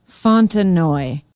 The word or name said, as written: Fontenoy